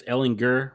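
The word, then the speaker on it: Ehlinger: Is it Ellinger or is it Ellinger? How do you say it? – It's Ellinger with a Ellinger. You got hard G.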